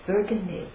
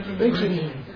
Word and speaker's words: burgeoning: And so you have this burgundy, how do you say the word, burgundy, burgundy? – Burgeoning.